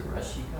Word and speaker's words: Rasika: Rasika on the last line, is it Rashika or Rasika? – It's almost always, S is almost always pronounced with like SH.